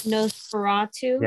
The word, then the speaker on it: Nosferatu: I think there was a nose to Rothu. Well, how do you pronounce that? – Nosferatu. – Yeah. Nosferatu.